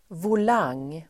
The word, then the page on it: volang: [vol'ang:]